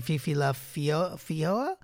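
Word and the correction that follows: Feijoa: Fijoa, sorry. – Fijoa.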